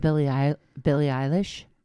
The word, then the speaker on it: Billie Eilish: Did you know that Billie Eilish, is that how you say her name? – Yeah.